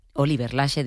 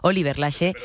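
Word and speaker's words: Oliver Laxe: El español Oliver Lache, Dios mío, parece mentira que soy gallega y lo digo mal. Oliver Lache. Vale, gracias, Dani.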